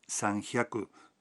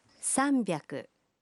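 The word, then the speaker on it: sanbiaku (さんびゃく): 300 no se pronuncia sanjiaku, sino sanbiaku.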